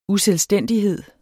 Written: uselvstændighed

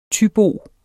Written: THYBO

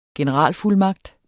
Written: generalfuldmagt

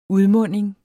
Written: UDMUNDINGEN

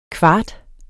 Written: kvart

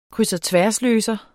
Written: krydsogtværsløser

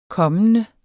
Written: kommende

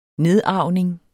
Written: nedarvning

